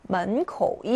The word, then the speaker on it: 门口: 자, 门口예요. 门口, 이성, 삼성이죠. 입구라는 뜻입니다. 우리 공원 입구에서 만나자예요. 门口, 이성, 삼성 찾는 건데요.